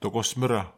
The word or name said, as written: tukosmera